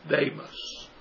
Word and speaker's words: Demas: And then he goes in verse 10, he talks about Demas, or I believe the way the Greek pronounces it is Demas.